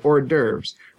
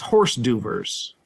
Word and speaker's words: hors d'oeuvres: But we say hors d'oeuvres. What we don't say is horse d'oeuvres. You know, that's the way it's spelled, but we don't pronounce it that way. We pronounce it as close to the French as the American tongue can.